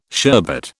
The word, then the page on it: sherbet: British English